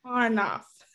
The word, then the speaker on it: Arnoff: I may have mispronounced your last name. Thank you for joining me today, Allison. – Glad to be here. Thanks for having me. And it's Arnoff. – Arnoff. My apologies for mispronouncing it.